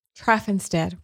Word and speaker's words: Traffanstedt: Dr. Traffanstedt, right? – Right.